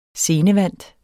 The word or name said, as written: scenevant